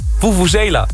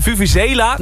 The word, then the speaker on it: vuvuzela: Vuvuzela. Ja, zo spreek je het uit volgens mij.